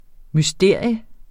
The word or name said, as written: MYSTERIERNE